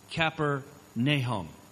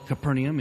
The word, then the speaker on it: Capernaum: And he actually had a gentleman that was leading his tour that told him, you're saying it wrong. It's not Capernaum. It's Capernaum. Capernaum. Capernaum. Capernaum. Capernaum. Like the minor prophet Nahum.